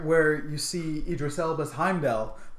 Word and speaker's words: Heimdall: Idris Elba's Heimdall.